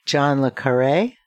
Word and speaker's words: John le Carré: And another iconic writer, John le Carré, is that how you pronounce it? – Mm-hmm.